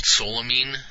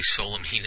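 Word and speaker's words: solanine: That green stuff, when it starts getting under the skin, it's a compound called solamine. I believe that's how it's pronounced. And that's actually what gives you a really bitter flavor. And actually, solamine is, if you have enough of it, it's actually poisonous.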